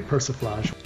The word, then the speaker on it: persiflage: I am not familiar with the word persiflage, saying that correctly. – You are saying it correctly, persiflage.